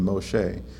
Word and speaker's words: Moshe: Actually, Moses is a little different than Moshe, as it would have been pronounced, and Abraham is a little bit different than Abraham.